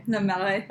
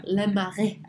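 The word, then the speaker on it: Le Marais: How do you say? – La Marais. – Oh, sorry. La Marais.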